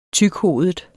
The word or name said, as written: tykhovedet